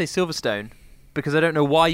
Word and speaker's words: Silverstone: Silver Stun or Silver Stone? Scene or Sin? The latter is a Palmer pronunciation and it gets on my tits.